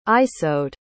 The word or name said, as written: isoud